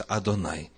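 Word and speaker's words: Адонай (יהוה): Первое слово «еварыхаха», второе «ехва», по традиции произносится Адонай.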